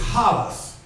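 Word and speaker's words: halas (ἅλας): In the Greek word is halas. H-A-L-A-S.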